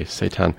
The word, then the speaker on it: Seudan: Then we moved on to a brand new recording by Seitan, that's spelled S-E-U-D-A-N, but pronounced in the satanic way, Seitan.